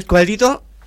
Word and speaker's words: Squadrito: Y bueno, usted anunció ahí a Angie Escobedito. ¿Está bien pronunciado el apellido? – Sí, muy bien, sí.